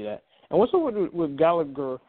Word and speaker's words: Gallagher: Oh, I say Gallagher because that's the way how you pronounce it, but apparently WWE wants to pronounce it Gallagher for whatever reason because the H is usually silent, but that it is what it is.